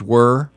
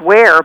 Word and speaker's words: Wehr: And it's Beata, B-E-A-T-A, and the last name is W-E-H, is that right? – W-E-H-R. – Oh, E-H-R. – Yeah. – Okay, pronounced were. – I think it's where, but I'm not positive.